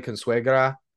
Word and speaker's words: Consuegra: You know, they have like one guy, Stanley Consuegra. That was pretty good pronunciation if I got that right. Sounded good, didn't it? – You nailed it, man.